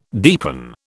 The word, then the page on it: deepen: British English